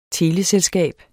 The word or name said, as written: teleselskab